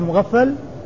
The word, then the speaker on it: مغفل: شخص واحد هو مغفل وهو والد عبد الله الصحابي عبد الله بن مغفل والده مغفل بالغين والفاء المشددة.